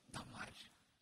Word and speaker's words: Дамарь: И женщина Дамарь. Удобнее так произносить, но правильное ударение греческое «дамарь» на первую «а».